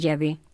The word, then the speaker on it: Yahvé: Y si tomáis un diccionario bíblico de categoría, como por ejemplo el del padre Ausejo, de Editorial Herder, dice Jehová, falsa pronunciación del nombre de Yahvé.